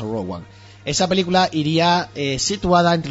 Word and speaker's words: Rogue One: Rogue One es Rogue One. – Ah, o sea, Rogue, lo dije mal, ¿no? – Se pronuncia Rogue, en realidad, Rogue One, o sea, Escuadrón Rogue 1, o sea, Rogue One.